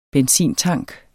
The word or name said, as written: benzintank